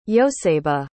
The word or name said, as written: yoseba